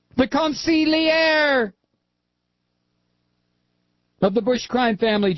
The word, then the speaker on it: consigliere: Okay, so I didn't pronounce it right. Consigliere. The consigliere. Well, you try to pronounce it. It's hard. It's like French-Italian. It's very difficult.